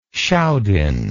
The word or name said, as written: schaudinn